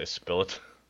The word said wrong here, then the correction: Bilott: I don't know. That's what you said. – I think I got the last syllable right, but not the first.